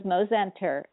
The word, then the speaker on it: Mozenter: Also, you can reach me at Facebook, Bonnie Mosenter Albers, so I'll spell it for you, B-O-N-N-I-E, and then my maiden name was Mosenter, M-O-Z-E-N-T-E-R, Albers, A-L-B-E-R-S.